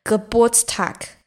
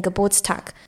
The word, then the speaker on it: Geburtstag: 那今天要教的这个单字就跟生日有关系，它就是德文的生日这个字，这个字怎么念？这个念 Geburtstag，就是 Geburt。 Tag Tag 是天 day 的意思，所以就有点像 birthday，然后就变成 Geburtstag，这个字念 Geburtstag。如果你要祝人家嗯嗯生日快乐，用德文讲其实很真的很难，我自己念一念我都觉得很难。就是啊，我想一下哦 Herzlich Glückwunsch Geburtstag zum Geburtstag，Herzlich Glückwunsch zum Geburtstag，就是整句话是这样念。